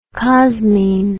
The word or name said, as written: cosmine